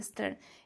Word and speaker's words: Stern: Stern, se, pronuncia Stern, eh, se escribe e S-T-E-R-N, Stern eh,